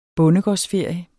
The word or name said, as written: bondegårdsferie